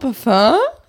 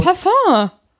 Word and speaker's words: Parfüm: Und dann habe ich das Parfüm genommen, Parfum. Wie spricht man Parfüm denn eigentlich aus? – Parfum. – Oder Parfüm. – Wie Cousin und Balkon.